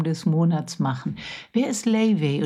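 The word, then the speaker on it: Laufey: September 25 und wie ich in der Zwischenzeit gelernt habe, Layvay wird der Name ausgesprochen.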